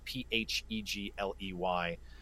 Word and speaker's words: Phegley: But it's it's just K I E L P H E G L E Y.